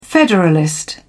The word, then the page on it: federalist: British English